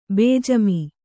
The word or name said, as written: ಬೇಜಿಮೆ